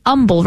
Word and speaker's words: Humble: Goes to St. Mary Magdalene, and it's an humble, right? Humble. There's no H in humble, right? – Well, there is an H, but it is silent. – It is silent. It's not there audibly.